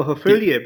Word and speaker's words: Herfølge: Then the next season, a team who I can't pronounce, Herfel. – Oh, Herfølge, but that's Hobie Kuhn now.